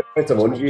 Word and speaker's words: Pret a Manger: After a career spanning the likes of Pret a Manger, how do you pronounce that? Is it Pret a Manger? – Pret a Manger, yeah.